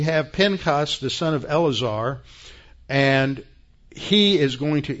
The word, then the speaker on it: Eleazar: It's Eleazar. The English puts an extra E in the name, Eleazar, but there's no second E in the Hebrew. It's Eleazar. And it's like the second E in, or the first E in Phinehas.